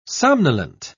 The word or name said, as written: somnolent